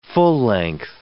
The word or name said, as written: full-length